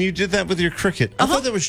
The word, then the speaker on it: Cricut: C-R-I-C-U-K. – It's Cricut. – C-R-I-C-U-K. – Oh, C-U-T.